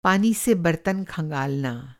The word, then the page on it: खँगालना: کهنگالنا